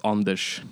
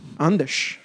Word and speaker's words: Anders: You know, you can say Anders, you can pull the A out, so it could be Anders. – Okay. – But if you're in Sweden, it's actually pronounced Anders. – Anders.